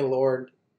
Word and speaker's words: Lorne: How do you say his name? Is it Lauren or Lorne Michaels? – Lorne? – It's Lorne Michaels of SNL fame.